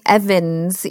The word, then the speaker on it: Ewens: It looks like Ewens. It's Ewens. But it's pronounced Evans. It's weird. I know. – it is pronounced Evans. Okay.